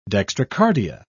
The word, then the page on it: dextrocardia: British English